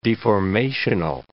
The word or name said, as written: deformational